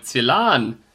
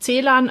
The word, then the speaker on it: Celan: Aber er selber spricht sich Celan, also wollen wir auch Celan sagen.